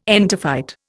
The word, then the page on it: entophyte: Indian